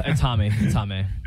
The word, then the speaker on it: Jaime: Spanish pronunciation, we're going Jaime or are we going Jamie? – It's Jaime.